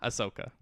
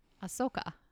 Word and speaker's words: Ahsoka: Ahsoka. Yes. Very, very good episode. – Soak. – There's an H. H after the A. – I'm just doing it phonetically. – Oh, Ahsoka. – Ahsoka.